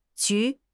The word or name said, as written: ju2